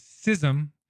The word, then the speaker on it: schism: The correct pronunciation is schism, but it's morphed into schism, which is the popular understanding. Only a few people say schism anymore, but that's actually the correct, pure pronunciation. But we'll go with schism now because that's the popular understanding of the pronunciation.